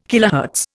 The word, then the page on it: kilohertz: Indian